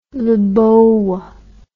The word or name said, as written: Lebowa